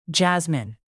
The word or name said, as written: jasmine